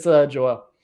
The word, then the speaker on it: Joel: It's Joel. – Joel. Manuel Joel Zavala. Excellent. – Yeah. So if you say Manuel, it rhymes.